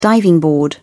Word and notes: diving board: British English

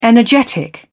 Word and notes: energetic: British English

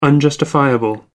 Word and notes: unjustifiable: American English

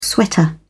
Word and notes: sweater: British English

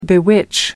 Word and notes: bewitch: American English

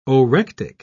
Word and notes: orectic: British English

